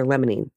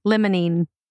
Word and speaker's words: limonene: or limonene, sorry, I always say it wrong. – It's all right, limonene.